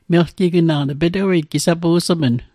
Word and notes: melgignaq: Mi'gmaq

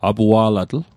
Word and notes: apua'latl: Mi'gmaq

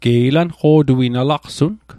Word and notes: laqsun: Mi'gmaq

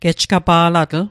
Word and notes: gejgapa'latl: Mi'gmaq

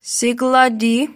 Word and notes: siglati: Mi'gmaq

